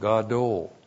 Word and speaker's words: gadol: G-A-D, long O-L, gadol.